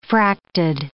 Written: fracted

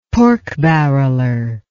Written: pork barreler

